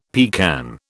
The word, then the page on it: pecan: British English